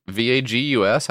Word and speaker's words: vagus: The vagus nerve Am I saying that right? V A G U S? How do you say that? vagus nerve.